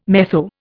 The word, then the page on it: metho: Indian